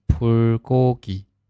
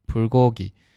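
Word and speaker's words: 불고기: 好，然后下一个是烤肉，汉语发音呢是 불 고 기， 불 고 기，其实它是由两个单词构成，第一个呢是 불，代表火或者呢灯，在这里呢表示火了，对吧，然后 고 기 表示肉，所以来表示烤肉，也可以联想到，对吧。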